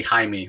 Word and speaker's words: Jaime: I called him Jamie again, so yeah, I don't even say the name right. But it's the Latin pronunciation of Jamie would be Jaime, but Jace sounds like an H.